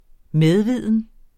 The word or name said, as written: medviden